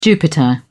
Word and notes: jupiter: British English

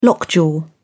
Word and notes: lockjaw: British English

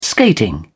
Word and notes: skating: British English

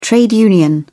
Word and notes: trade union: British English

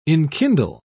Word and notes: enkindle: British English

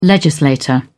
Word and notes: legislator: British English

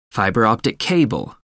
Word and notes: fibre optic cable: American English

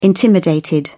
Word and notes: intimidated: British English

